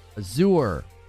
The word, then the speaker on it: Azure: Oh, Azure? Like a cure?